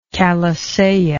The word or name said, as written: calisaya